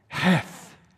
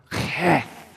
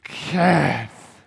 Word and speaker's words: Heth: I can see him pondering and meditating on God's word as he encounters this 8th letter in the Hebrew alphabet, Heth, or Keth. Pronounce the letter with me, Keth, Keth. Clear your throat.